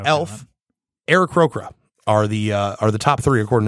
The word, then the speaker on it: Aarakocra: I'm really bad at saying Aarakocra.